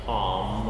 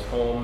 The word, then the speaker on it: Ham: probably the better Hebrew pronunciation would be Ham, I guess. Home, maybe something like that. But we call him Ham.